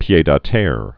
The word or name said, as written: pied-a-terre